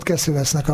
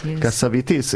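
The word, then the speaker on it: Cassavetes: Azt mondta, tudod, hogy hogy kell Kaszavetesz nevét kiejteni? Kaszavitisz. Igen, én mindig Keszövesznek mondtam. – Én is Keszövesznek mondtam. – Igen, egy amerikai barátnőmtől hallottam. – Ja, kiejtette, és így kell.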